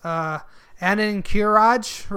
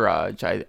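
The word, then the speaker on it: Ahn'Qiraj: But speaking on hitting on nostalgia, World of Warcraft's classic Ahn’Qiraj, I don't know how to say this, Ahn’Qiraj raid is coming out. Ahn’Qiraj, I believe it would be pronounced.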